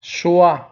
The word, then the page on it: choix: son wa